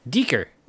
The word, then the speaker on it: dhikr: So the first one is D-H-I-K-R. – Deeker.